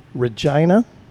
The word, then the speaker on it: Regina: So they moved around a lot, and he spent a number of years in Winnipeg, Regina, Calgary, did I say that right? Yep.